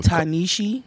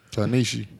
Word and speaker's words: Ta-Nehisi: Ta-Nehisi. – That's how you say it? – Yes. – Ta-Nehisi.